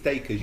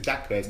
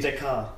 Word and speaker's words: Dacres: I think it's Dacres. You just said Dacres. – Dacres. – I'm sure I read somewhere that someone tweeted... – Dakar. – He's Jamaican, not French. It's Dacres, not Dacres.